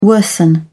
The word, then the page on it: worsen: British English